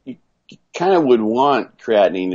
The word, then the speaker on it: creatinine: Creatinine. Wait, am I saying it right now? – Creatinine. – Creatinine. I always want to say like creatine with an extra syllable.